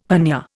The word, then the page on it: hernia: Indian